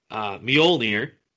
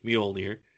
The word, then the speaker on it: Mjolnir: My next pick is Mjolnir from Thor. – Thank you for pronouncing it correctly. – I know how to pronounce it correctly, Mjolnir.